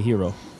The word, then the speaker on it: gyro: That's how do you say that word? – I say hero. Famous Hero Bowl. Famous Gyro Bowl. – Gyro. – That's what you weirdos call it, but yeah.